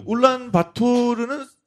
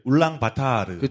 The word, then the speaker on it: 울란바토르: 네, 네 그 울란바토르는 사실은 그 이제 러시아식 발음이고 네. 몽골식으로 발음을 하면 이제 울랑바타르.